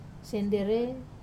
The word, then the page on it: senderei: wamesa